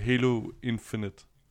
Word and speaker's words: Halo Infinite: Skal vi tage den første, fordi altså, det er Halo Infinite.